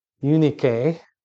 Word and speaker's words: Eunice: Eunice is Timothy's mom, Eunice, is how you pronounce it.